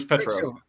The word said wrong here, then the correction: Pietro: Pietro. – Yeah, Pietro.